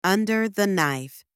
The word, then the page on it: under the knife: anglais